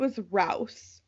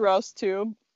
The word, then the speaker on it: Rouse: I think it's Rouses based on the way that I've heard it pronounced, but it could be like Ronda Rousey too. – I was gonna say, I think it was Rouse. R-O-U-S-E.